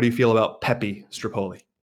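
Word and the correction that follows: Stirpoli: Well, I butchered his last name. Steropoli. There you go. – Steropoli.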